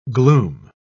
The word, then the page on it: glume: British English